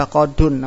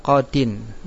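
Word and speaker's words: qadin (قَاضٍ): Dahaba qadun, namun kita enggak baca, namun kita bukan itu bacanya. Salah kalau dibaca qadun. Kenapa? Karena di situ ada ya yang dihapus diganti dengan tanwin, namanya tanwin iwat. Dahaba qadin.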